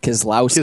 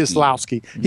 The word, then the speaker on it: Keselowski: Keselowski. – Keselowski.